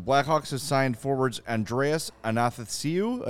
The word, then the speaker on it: Athanasiou: Athanasiou. – It's like. – Athanasiou. – It's like Nathan without the N.